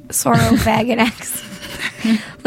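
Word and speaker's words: Saurophaganax: Sorofaginex. It's weird. Sorofaginex. Literally, that's how it's said. I have the pronunciation right here.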